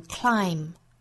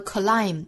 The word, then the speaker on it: climb: 还有一点呢，需要注意就是最后的字母 b 是不发音的，是一个 silent b。这个词我们要把它发成 climb 是单音节的词啊，是一个音节。如果发成了 climb，就是双音节了嘛。